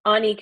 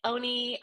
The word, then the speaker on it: Onni: Am I saying it right, by the way? Onni Creative. It's that's where the name comes from. Onni Creative. It's okay. I get Oni. I get Omni.